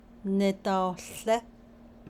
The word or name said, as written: nidaohłé